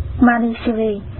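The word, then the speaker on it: Mañjuśrī: Ngài Văn Thù Sư Hị, nguyên tiếng Ấn Độ đọc là Manishri, chữ Hán là Diệu Đức hay là Diệu Cát Tường.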